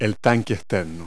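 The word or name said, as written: external tank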